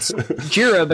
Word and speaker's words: Jira: it's Jira because it's named after Godzilla, Gojira, right? – Yeah.